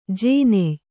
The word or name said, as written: ಜೋನಾಯ್